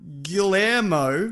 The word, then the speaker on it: Guillermo: Guillermo. Did I say it right? – It depends who you're talking about. – Del Toro. – Then yes.